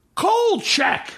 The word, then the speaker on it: Kolchak: Uh, there's also a continuing thing where he has to be like Kolchak, where like no one can understand his name. It's a very straightforward name. It's two syllables. – It's not hard. Yeah. Kolchak. – They're hard K sounds.